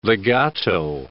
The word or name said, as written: legato